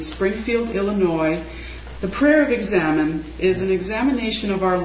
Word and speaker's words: examen: The prayer of examine, since you haven't seen it written down, the word is spelled E-X-A-M-E-N, not examine like an examination.